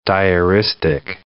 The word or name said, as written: diaristic